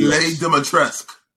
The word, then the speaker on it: Dimitrescu: Lady Dimitrescu. Lady Dimitrescu. That's how you pronounce it.